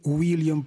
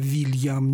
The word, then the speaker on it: Уильям: И сказать, что «Уильям» правильно, а «Вильям» неправильно, или наоборот сказать, невозможно. И то, и другое верно.